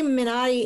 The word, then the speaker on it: Minari: I mean, they say Minari in the movie, don't they? – Yes, that's how I heard it in the movie. – Okay, well, fine. Then I'm going to stick with Minari.